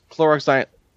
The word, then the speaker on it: chloroxylenol: Chloroxylenol. Roxylenol. Yes. – No, that's an X. – It's chloroxylenol.